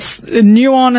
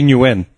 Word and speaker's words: Nguyen: I'm like, what the hell is that clearly Greek building doing with Nguyen and Sons advocates? – It's actually Nguyen. – Nguyen. Exactly.